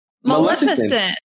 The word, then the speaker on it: Maleficent: Maleficent. Right? – It's like malevolent, but I'm confused now.